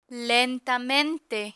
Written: lentamente

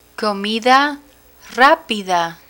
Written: comida rapida

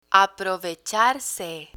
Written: aprovecharse

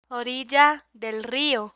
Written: orilla del rio

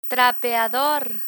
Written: trapeador

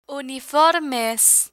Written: uniformes

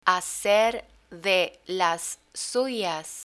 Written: hacer de las suyas

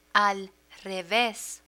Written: al reves

